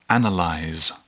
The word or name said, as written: analyze